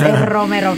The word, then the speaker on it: Romero: Can you help some of us? You got to roll your R's. Romero.